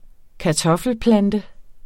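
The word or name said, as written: kartoffelplante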